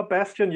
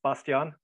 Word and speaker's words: Bastian: Well, Bastian, yeah, it's the English pronunciation in Germany. Bastian.